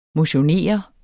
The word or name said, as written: motionere